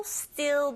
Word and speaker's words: still: Still 발음하실 때 짧게 발음하시고 st 보이시죠? t 발음 된소리 내셔서 발음하고 들리게 됩니다. Like here.